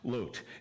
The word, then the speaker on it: Lot: I'm trying to condition myself to start calling him by the way his name is actually pronounced, Lot.